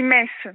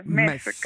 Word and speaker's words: Metz: On dit Metz. Metz. Comme si, voilà, on ne prononce pas le T.